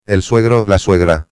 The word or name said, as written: el suegro/a